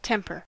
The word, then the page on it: temper: American English